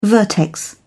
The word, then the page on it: vertex: British English